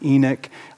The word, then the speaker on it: Enoch: You hear Enoch, you hear Enoch, that can sort of like dual pronunciations here. And so I'll go with Enoch. That's kind of how I first heard it pronounced here.